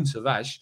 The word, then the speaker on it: Tavares: And Nuno Tavares, or Nuno Tavares, as we should pronounce him correctly, Nuno Tavares has scored and got two assists as Portugal's under-21 side beat Liechtenstein's under-21s 11-0.